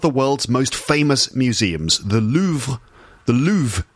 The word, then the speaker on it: Louvre: In Paris, the Louvre, that's how we say it in English. Yes, the Louvre, you probably know it. The Louvre, yes, that is my English pronunciation of the place. I wonder how you pronounce it where you are from. In French, I think it's like the Louvre, right? The Louvre or le Louvre.